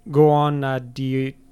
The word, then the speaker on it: Guðnadóttir: Guðnadóttir.